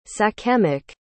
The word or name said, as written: sachemic